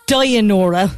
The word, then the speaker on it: Dynoro: Dynoro or Dynoro? What would you say? What's your pronunciation? – Oh, good. Dynora.